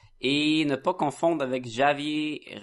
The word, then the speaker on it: Javier: C'est Javier. C'est en, Javier Fernandez. lopez C'est de l'espagnol, Sacha. Tu prononces pas le, J.